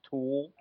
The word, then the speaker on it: Thul: So, Nikki, just so you know, his last name is spelled THUL. It's pronounced Tool.